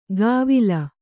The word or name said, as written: ಕವಿಲ್